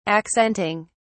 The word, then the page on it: accenting: British English